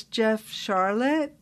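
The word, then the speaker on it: Jeff Sharlet: You know, I've always pronounced your name as Jeff Charlotte. Is that correct? – Yep.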